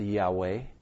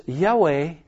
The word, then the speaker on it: Yahweh: Now, some have put vowels in there to say that it was pronounced Yahweh.